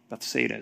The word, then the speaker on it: Bethsaida: There's Bethesda. Bethsaida. I'm saying the wrong. Bethsaida is how you say that.